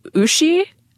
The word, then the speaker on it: Uschi: but Uschi, which apparently rhymes with Mushi, which is apparently a German slang term for vagina.